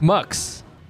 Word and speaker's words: Mx: Did you just say like? M. Mux. – Mux. – Mux.